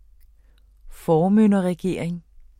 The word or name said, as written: formynderregering